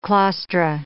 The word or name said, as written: claustra